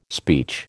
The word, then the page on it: speech: American English